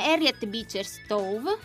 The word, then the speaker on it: Harriet Beecher Stowe: Il libro quindi è stato scritto dall'americana Harriet Beecher Stowe, sì. – Se si pronuncia così,